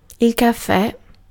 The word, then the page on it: café: [ɛ̃ ka.fe]; [lə ka.fe]